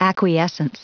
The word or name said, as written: acquiescence